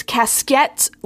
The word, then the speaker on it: casquette: It's casquette, like French, but colloquially, it's spelled casket.